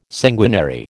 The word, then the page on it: sanguinary: American English